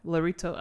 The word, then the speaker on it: Lorito: Lorito. I'm not saying it right. – Like Dorito. – Yeah. Dorito, but Lorito.